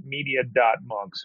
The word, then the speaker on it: Media.Monks: Rebranded as, I guess you would pronounce it, Media.Monks, or it's Media Monks with a period in the middle.